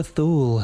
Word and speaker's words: Thule: Ultima Thule has reappeared in the news. – As apparently it's pronounced Thule. I've been corrected on that. It's meant to be Thule or Thule.